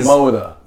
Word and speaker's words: Moda: Sir, it's Moda. – Moda? I'm sorry. Moda Singletary is out here balling. – Say it like you drink iced tea from a straw.